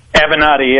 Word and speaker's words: Avenatti: Is it Avenatti? Am I saying it right? – Avenatti, yeah.